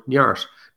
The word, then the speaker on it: Neart: I use an Irish word called Neart, N-E-A-R-T, pronounces an Irish Gaelic word, Niart.